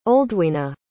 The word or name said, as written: Oldwina